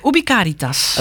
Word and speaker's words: Ubi Caritas: Het Ubi Caritas, als ik het goed uitspreek. – En Ubi Caritas. – Ubi Caritas, natuurlijk. De klemtoon ligt anders.